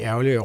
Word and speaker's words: ærgerlig: Og der er jo de ære legio, de eksempler, man kan finde på ting, som folk bliver rigtig ærgerlige over. Her for en måneds tid siden... – Det hedder ærgerlig. – Ærgerlig. Ja, det er så udtalen, du hæfter dig derved.